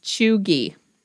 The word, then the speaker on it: Cheugy: That's how you pronounce it. Yeah, but it's spelled Cheugy. So I would have said chuggy.